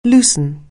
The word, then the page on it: loosen: anglais